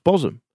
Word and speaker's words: Bosham: Yeah, and the next one is spelt Bosham. – Bosham. – Bosham. – Cosham and Bosham. – Cosham and Bosham. – Bosham. – It's pronounced Bosham. There's absolutely no fucking reason for it.